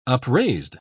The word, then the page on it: upraised: British English